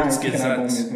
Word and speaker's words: Kurzgesagt: Tem um canal maravilhoso que eu não sei pronunciar, eu acho que é Kurzgesagt. Kurzgesagt. – Ah, Kurzgesagt.